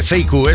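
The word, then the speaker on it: Sekou: que sepas que se escribe Seikou, pero se dice Seiku, eso que quede bien claro, ¿eh?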